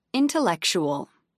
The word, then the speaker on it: intellectual: Intellectual. 5 syllables, main stress on the third syllable.